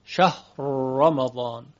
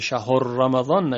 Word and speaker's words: شهر: شهر رمضان، شهر رمضان. بعد دقت کنیم که ها ساکن تلفظ بشه، یک وقت مضموم نشه، شهر رمضان نشه.